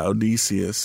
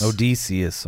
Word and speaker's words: Odysseus: Well, it's about Odysseus, if that's even how you pronounce that. – Yeah, Odysseus. – Odysseus, all right.